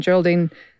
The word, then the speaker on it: Geraldine: And he has cast Margaret Qualley in it, along with Geraldine Viswanathan. And I hope I'm pronouncing that right. Apologies. – Geraldine is correct, yes.